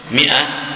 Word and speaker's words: مائة: لا الألف ما تنطق حفظك الله ترسب ولا تنطق. نعم مئتي لا مئتي سنة هو قد ترسم ماء لكن هي ما تنطق. مئة أحيانا أظن تجعل فيها ألف لكنها لا تقرأ.